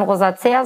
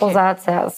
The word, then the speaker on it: Rosacea: Ja, man kann tatsächlich beides sagen. Man kann Rosacea sagen oder auch Rosacea.